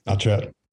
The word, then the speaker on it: Notchet: We know how you pronounce Notchet. Why? – Notchet. Yeah. I based it on not yet and not yet. So is the way that one comes out.